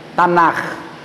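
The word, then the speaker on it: Tanakh: Tanar. É um R com H, sabe? É um então, toda vez que você a palavra hebraica que tem um Hzinho no final, você pronuncia Tanar.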